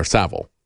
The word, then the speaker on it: Savile: Understand, this is not a problem with Jimmy Savile, or Savile, that's the way they pronounce it in the UK.